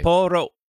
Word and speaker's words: Sapporo: Sapporo.